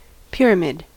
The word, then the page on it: pyramid: American English